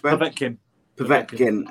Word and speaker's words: Povetkin: Russian boxer, whose name I'm about to butcher, Alexander, how is it pronounced? – Povetkin has announced he's retiring, citing lingering injuries that require treatment.